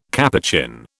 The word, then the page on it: Capuchin: British English